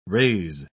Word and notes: rays: British English

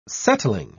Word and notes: settling: British English

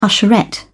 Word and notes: usherette: British English

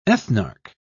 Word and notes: ethnarch: British English

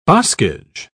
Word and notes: boscage: British English

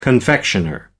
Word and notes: confectioner: American English

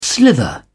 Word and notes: slither: British English

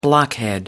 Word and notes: blockhead: American English

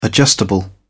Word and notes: adjustable: British English

